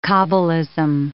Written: cabalism

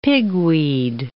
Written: pigweed

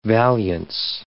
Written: valiance

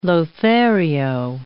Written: lothario

